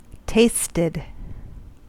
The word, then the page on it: tasted: American English